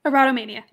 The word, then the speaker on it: erotomania: But the erotom- erotomania is how do you pronounce it? Erotomania, yeah.